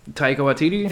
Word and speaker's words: Taika Waititi: Taika Waititi? – Thank you.